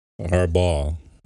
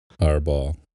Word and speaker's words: Harbaugh: Harbaugh. – Yeah, well. – Harbaugh. – That's like you saying hard balls, but to someone who lives in the ghetto. Sure. Hey, dog, you got them Harbaugh? – I get it.